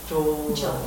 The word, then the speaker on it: Joel: and you're Joel Johnson. My first name is spelled J-O-E-L. It's named J-O-H-N-S-O-N.